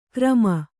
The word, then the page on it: ಕ್ರಮ: krama